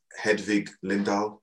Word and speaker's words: Hedvig Lindahl: Is it let me get this right, it's Hedvig Lindahl, I hope I pronounced that correct.